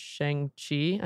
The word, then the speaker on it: Shang-Chi: and Shang-Chi.